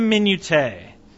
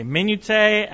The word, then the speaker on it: minutiae: And I was at brunch with my dad and Renee, and Renee goes, do you mean minutia? And I said, yeah, well, that's one way of pronouncing it. You could say minutia, you could say minutiae. And my dad looked at me and he goes, I've never heard it pronounced that way.